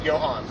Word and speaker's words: Johann: It's like C-J-O-H-A-A-N-N, however you pronounce it. I'm like, yeah, that's pronounced Johann.